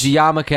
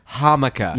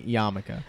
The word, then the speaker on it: yarmulke: Pronounced yarmulke as hamaka.